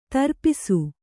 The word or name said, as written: ದ್ರಪ್ಸ